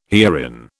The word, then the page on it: herein: British English